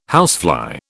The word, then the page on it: housefly: British English